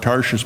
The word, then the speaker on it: Tarshish: People have laughed at the way I pronounced that word, Tarshish, but that's how you say it, T-A-R-S-H-I-S-H.